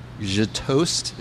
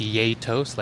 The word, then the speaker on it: gjetost: je toast. Is that how it's pronounced? – It's pronounced yay toast, like yay and toast. A very festive sounding cheese. – It's spelled G-J-E-T-O-S-T.